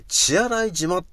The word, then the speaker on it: 血洗島: で、血液の血で洗う島と書いて、血洗い島っていうその場所なんだよね。